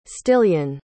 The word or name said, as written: stillion